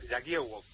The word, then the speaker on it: Jagiełło: Aquí se llama Jagiełło, una especie de letra, una doble L partida que se pronuncia como W.